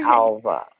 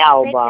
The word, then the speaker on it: Alba: Alba. É Alba, Perdão.